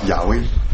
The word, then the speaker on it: 雅威: 比较接近的，应该叫雅威，会更接近原文的发音。耶和华其实跟原文的发音，这都是音译的啊，跟原文的发音，相对而言会比较远一点。雅威呢是更近一点。